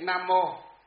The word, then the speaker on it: Nam Mô: Cho nên là đây chúng ta người học Phật chúng ta, nhà Phật chúng ta không đọc chữ này là vô, mà đọc là gì? Mô. Chữ Nam Mô.